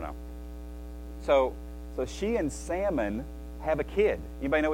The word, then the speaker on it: Salmon: It's how you pronounce it, too. Salmon. S A L M O N.